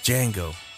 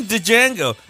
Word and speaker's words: Django: He was a jazz guitarist, so I know that the name is Django. But even in the movie, Jamie Foxx tells you it's Django. The D is silent.